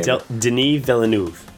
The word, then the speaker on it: Denis Villeneuve: She did the film Enemy, the uh, Dennis Villeneuve. I can never pronounce his name. – Denis Villeneuve. Okay, well, I'm going to have to learn to do that by the time Arrival opens this fall.